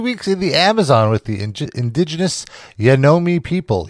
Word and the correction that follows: Yanomami: Yanomami. – Oh. – Say it right. Yanomami.